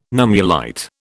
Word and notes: nummulite: British English

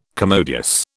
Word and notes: commodious: British English

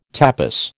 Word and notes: tapis: American English

